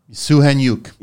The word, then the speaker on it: Soukhaniouk: Soukhaniouk. – Yeah. – And I know how it's spelled here because Rocco told me that. Right? – It's a crazy spell. Like, yeah. – So Soukhaniouk.